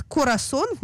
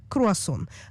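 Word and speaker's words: круассан: Собственно, почему некоторые израильтяне, ну не все, конечно, говорят «корасон» вместо «круасон», «дардарант» вместо «дезодорант», а слово «акзоз» и «пункчер» стали давно частью разговорного иврита.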